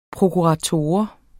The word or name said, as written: prokurator